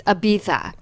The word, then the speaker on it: Ibiza: The other day someone told me, if you're going to name a bronzer Ibiza, you need to say it correctly. It's Ibiza.